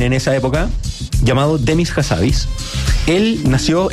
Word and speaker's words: Hassabis: Demis Hassabis. ¿Se dice así? Hassabis. – Hassabis. Es un personaje. – Habibi, iba a decir como Habibi.